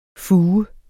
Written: fuge

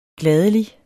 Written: gladelig

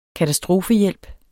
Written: KATASTROFERNES

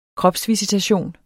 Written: kropsvisitation

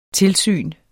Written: tilsyn

